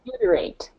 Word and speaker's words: butyrate: And the word, is it butyrate, or is that how you say it? – Butyrate, yeah.